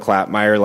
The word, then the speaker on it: Klapmeier: My name and committee members, my name is Dylan Klapmeyer. Last name is spelled K-L-A-P-M-E-I-E-R, and I'm Governor Gianforte's education advisor.